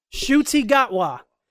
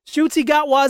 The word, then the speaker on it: Ncuti Gatwa: Ncuti Gatwa. Ncuti Gatwa is the new Doctor Who, y'all.